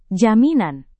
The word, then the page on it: jaminan: Indonesian